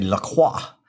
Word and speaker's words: LaCroix: Well, in France or in Canada, in Quebec, they say LaCroix, which is technically the proper pronunciation.